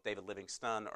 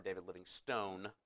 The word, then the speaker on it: Livingstone: His name, I've heard it pronounced both David Livingston or David Livingstone. At one point in his life, he added the E to his name, so I don't know if he wants to be Stone or Stun, but I'm going with Livingston because I've heard more people say that.